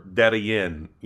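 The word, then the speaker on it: Darién: But now, you know, now here I am, and I've gone to both sides of the Darien Gap, or Darien, you know, as it's pronounced here.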